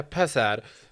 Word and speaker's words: pazar: Eh, jaha, det så, uttalas på turkiska Pazar.